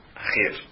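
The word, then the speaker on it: Acher (אחר): E lá a Torá fala sobre, então, deuses outros, se diz Acher. Acher. Se escreve Aleph, Het, Reish. Como se escreve Echad, um, Deus único, Aleph, Het, Dalet.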